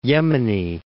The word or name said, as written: South Yemeni